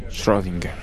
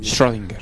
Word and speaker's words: Schrödinger: Schrödinger. – ¿Cómo es? – Schrödinger.